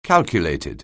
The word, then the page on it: calculated: anglais